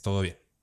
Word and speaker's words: TDBN: Bueno, se escribe TDBN, pero es Todo Bien.